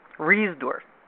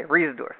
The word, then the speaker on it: Reisdorf: Reisdorf. Okay, Reisdorf.